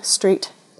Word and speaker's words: Street: And my name is spelt T-A-T-H-R-A, and my surname, Street, just like road, S-T-R-E-E-T.